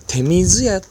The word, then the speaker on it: 手水屋: 屋またはですね、手水屋と読みます。